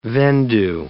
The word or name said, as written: vendue